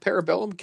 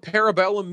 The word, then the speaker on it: Parabellum: John Wick Parabellum? Is that how you pronounce it? – Yes. – John Wick Parabellum. Kendrick, you also blogged about John Wick today. Do you know what Parabellum means?